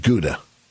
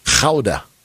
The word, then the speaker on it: Gouda: But they don't pronounce it Gouda. – Okay. – It's Gouda. – Okay. – With a little Gouda.